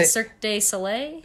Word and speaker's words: Cirque du Soleil: Circus Soleil, or however you, how do you say? – Cirque de Soleil? – Cirque. – It's definitely Cirque.